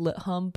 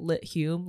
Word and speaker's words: Lit Hum: Typically known as Lit Hum, but I've heard one person pronounce it Lit Hume, like the U Chicago people.